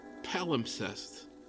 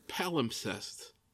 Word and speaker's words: palimpsest: It's called Palimpsest.